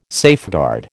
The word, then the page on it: safeguard: American English